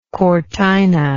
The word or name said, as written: cortina